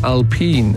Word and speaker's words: Alpine: Jared says in Formula One racing, they have a team spelt as Alpine, but they pronounce it as Alpine. And yeah, that's because it is a French subsidiary. It's a car that's, I think, a part of Renault or Citroën or something. And it's French, and the French say Alpine, not Alpine.